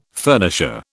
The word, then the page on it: furnisher: British English